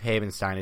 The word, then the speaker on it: Havenstein: I believe Havenstein is right. Correct me if I'm wrong, please. I would genuinely like to know that. Rob Havenstein signed a 4-year, $32 million extension with $16 million guaranteed.